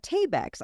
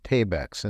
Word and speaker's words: Tabex: Now, our next quick update, thank you for that, is Tabex.